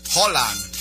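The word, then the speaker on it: Haaland: Holland. – Ahora dicen que así se pronuncia.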